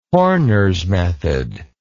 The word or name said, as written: Horner's method